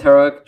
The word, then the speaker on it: Heroek: And it's Heroek, just like the adjective.